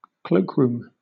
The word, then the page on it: cloakroom: British English